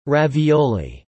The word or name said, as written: Ravioli